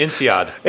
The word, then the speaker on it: INSEAD: And with his, I'm going to promote him a little, with his MIT and, how do you pronounce it, NSAID? No, NSAID. That's INSEAD. – INSEAD. NSAIDs and INSEAD are different, I gather.